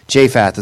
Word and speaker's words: Jephthah: Japheth is how I think I say his name. If you ever listen to it on like the Bible app, and you listen to the Bible app say his name, that's how it pronounces it, because that's how I'm pronouncing it, because I listened to how it was saying, and I'm like, I'll go with that.